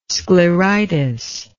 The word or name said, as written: scleritis